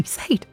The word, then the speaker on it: Sade: It's spelled S-A-D-E, Sade. But that's not how you say it, Sade.